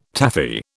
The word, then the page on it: Taffy: British English